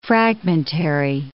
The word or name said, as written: fragmentary